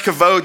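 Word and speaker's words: kavod (כבוד): Listen, when we see this word in Hebrew for glory, it's pronounced kavod.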